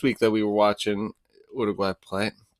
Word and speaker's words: Uruguay: So we were watching Uruguay. We learned how to say it the right way. It's not Uruguay.